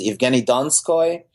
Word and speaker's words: Evgeny Donskoy: But he's definitely – I remember I saw him play a couple of years ago against – I think his name is Evgeny Donskoy, if I'm pronouncing it correctly.